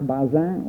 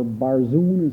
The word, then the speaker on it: Barzun: An example of a book in this area, one of the finest books, is that by Jacques Bazin, or Barzun as he's usually pronounced in this country, entitled The Modern Researcher, The Modern Researcher, or the work by the French historian Marc Bloch, entitled The Historian's Craft.